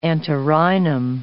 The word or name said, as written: antirrhinum